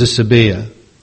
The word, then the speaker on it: asebeia: Number 9, the 9th Greek word, asebia, I think it's pronounced, A-S-E-B-E-I-A.